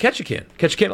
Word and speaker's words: Ketchikan: That's how you say it, Ketchikan, Alaska.